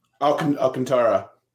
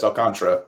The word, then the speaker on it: Alcantara: Alcantara. Excuse me. Alcantara. I apologize. Alcantara. Like the Iran Contra, but Alcantara.